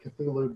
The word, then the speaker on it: Cthulhu: C-T-H-U-L-H-U, as in Call of Blank. How do you pronounce that name? – I've always gone with Cthulhu, but I've heard the harder, you know, Cthulhu as being, you know, quote-unquote also acceptable.